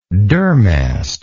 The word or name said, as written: durmast